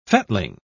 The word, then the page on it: fettling: British English